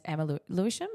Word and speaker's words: Emma Lewisham: And remember, so we also got like two really exciting follows on Instagram, Morris Makeup and Emma Lewisham.